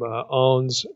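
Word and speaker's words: Aune: I'm not going to spend too much time on this, but if you recall last time I quoted from Ahn's commentary on Revelation, his word biblical commentary.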